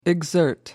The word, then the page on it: exert: American English